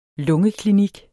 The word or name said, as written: lungeklinik